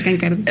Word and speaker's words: Cancardi: Porque eres baterista de, la, del grupo ah, General Cancardi. Sí. Sí, lo dije bien, porque de repente. No, así va General Cancardi.